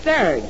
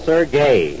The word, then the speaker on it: Serge: In the first place, his name is not Serge. I told you over and over to stop calling him Serge. – S-E-R-G-E. It's Serge. – It's pronounced Sergey.